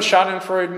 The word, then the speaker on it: schadenfreude: I was a German major, and in German we pronounce it schadenfreude, and I have no idea if in English you pronounce it which way, one or the other.